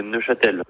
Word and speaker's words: Neufchâtel: C'est Neufchâtel. – Neufchâtel? – Oui, c'est bien ça. – Donc, on ne prononce pas le F? – Oui, exactement. J'ai toujours entendu Neufchâtel sans le F.